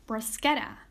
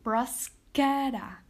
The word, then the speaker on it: bruschetta: So in this case, bruschetta has three syllables. Bruschetta. Once you break it down into syllables, look for the stressed syllable. Bruschetta. Bruschetta. In this case, it would be the ketta.